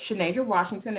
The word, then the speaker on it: Chenadra Washington: You can reach me primarily on my Facebook or LinkedIn at Chenadra Washington, and my name is spelled Chenadra Washington.